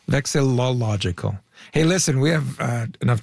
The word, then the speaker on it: vexillological: Well, I'm not even saying it right. Do you know the word vexillological? But it's not vexillological. It's harder to say than that. It's like vexillological. – It's You're missing a syllable. – Hang on. Vexillological. – That's it. Good job.